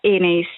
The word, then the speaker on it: Aeneis: No, si pronuncia Eneis, si scrive Aeneis, quindi la A e la E si pronunciano E assieme, è un nome latino, è il nome dell'Eneide,